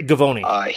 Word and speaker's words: Givony: I was watching a little ESPN yesterday, and is it Jonathan Givony? I'm trying to think of how you say his name. But anyway, Givony.